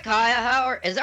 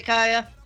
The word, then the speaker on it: Caia: Hi, Caia. Are we pronouncing it right? – It's Caia. Yes, you're doing wonderful. – Great. – How are you doing today, Caia? – Wonderful.